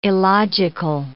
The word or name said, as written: illogical